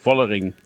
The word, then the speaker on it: Vollering: Zwischen F und V, Vollering. – Zwischendrin, Immanuel Vollering.